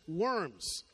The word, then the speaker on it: Worms: Now, Worms is spelled W-O-R-M-S, Worms. But in German, the W is pronounced as a V, so it's Worms.